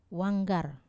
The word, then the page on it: wanggar: Wamesa